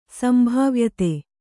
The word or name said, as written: ಸಂಭಾವ್ಯತೆ